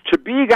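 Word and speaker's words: Chebeague: It's Chibeague Island, Maine.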